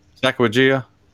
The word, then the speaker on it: Sacagawea: Is that the right way to pronounce that? – Sacagawea. Yep.